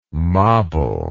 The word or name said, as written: moble